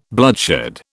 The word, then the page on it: bloodshed: British English